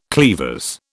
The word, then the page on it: cleavers: British English